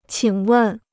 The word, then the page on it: 请问: qǐng wèn